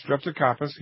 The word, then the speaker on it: Streptocarpus: I have a question, and I think this is how you pronounce it, Streptocarpus? – Yes, Streptocarpus, yes.